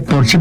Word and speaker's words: Porsche: O decir que tengo un Porsche en lugar de decir tengo un Porsche, porque en alemán Porsche se dice Porsche porque todas las es al final se pronuncian, como Marlene Dietrich, por ejemplo, que todo el mundo dice Marlene, pero es Marlene.